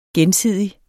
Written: gensidig

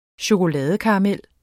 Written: chokoladekaramel

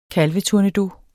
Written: kalvetournedos

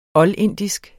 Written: oldindisk

